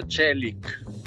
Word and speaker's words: Çelik: Si pronuncia Çelik, no Çelik, Çelik, che significa acciaio.